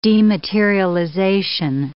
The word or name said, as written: dematerialization